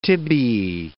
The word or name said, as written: tibiae